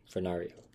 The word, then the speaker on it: Fornario: But her real name was Marie Emily Fernando. For Fornario. – I said it right the first time. I don't know why I did it wrong that time.